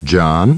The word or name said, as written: John